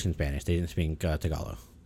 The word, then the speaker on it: Tagalog: Tagalog, thank you.